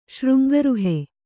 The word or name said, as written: ಶೃಂಗರುಹೆ